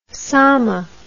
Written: somma

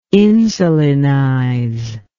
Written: insulinize

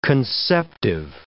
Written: conceptive

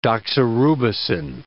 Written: doxorubicin

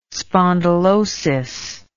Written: spondylosis

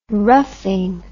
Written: roughing

